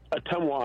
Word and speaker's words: Ottumwa: It's pronounced Ottumwa, Iowa.